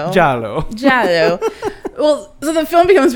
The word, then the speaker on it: Giallo: And then it just be, it, it gets super crazy, and yeah, it gets very, and I cannot pronounce the damn word, Giallo. – It's Italian for yellow, so just imagine it sounding like the word yellow, because that's what it is. Like, Giallo.